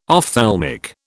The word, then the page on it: ophthalmic: British English